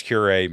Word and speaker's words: curiae: We now have a motion from the ACLU of South Carolina, and they want to file a brief of amicus curiae. Is that how you say it? – Curiae.